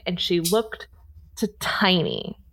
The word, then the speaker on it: Teine: Tiny is spelled T-E-I-N-E.